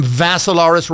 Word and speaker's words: Vassilaris: Dimitri with three I's, D-I-M-I-T-R-I. Last name Vassilaris rhymes with Assilaris, but you add a V to it.